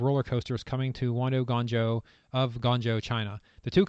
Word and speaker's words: Guangzhou: Dueling Intamin coasters are being built in Wanda Guangzhou, China, and I actually looked up how to pronounce that. It's Guangzhou.